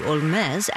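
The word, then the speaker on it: Holmès: Originally pronounced her last name like Sherlock Holmes, but Frenchified it to Olmés after becoming a citizen of France at the age of 24.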